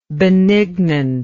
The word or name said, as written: benignantly